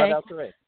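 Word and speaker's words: Pradal Serey: You study a Cambodian art, and forgive me if I say this wrong. Pradal Saray? – Yeah. – Did I say that right? Okay. – Pradal Saray.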